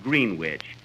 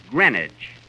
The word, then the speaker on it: Greenwich: Uh, Kenny, that's not Greenwich. You see, here in New York, it's pronounced Greenwich.